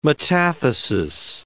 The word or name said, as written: metathesis